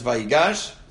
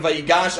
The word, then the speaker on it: Vayigash: It is not Parshas Vayigash. That's not the right way to say it. It's Parshas Vayigash.